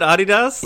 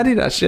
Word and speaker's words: Adidas: Do you actually pronounce it Adidas? – Adidas, yeah. It's it's Adidas in the company as well, so I think I'm kind of on my own here.